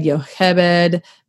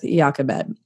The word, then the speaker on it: Jochebed: Now, I will be honest, there are like three different ways you can pronounce her name Jochebed, Yochebed, but I think we're going to go with Jochebed.